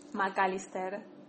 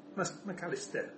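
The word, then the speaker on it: Mac Allister: Magalister. – McAllister.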